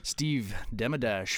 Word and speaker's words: Steve Demedash: So with us today is Steve Demedash from Demedash Effects.